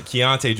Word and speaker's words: Keyonte: Keaton George or oh my god, I butchered his name. How'd I butcher his name? Uh, Keyonte George,